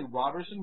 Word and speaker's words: Roberson: Is it Roberson or Roberson? – Well, technically Roberson with a short O, but I'll really answer to anything.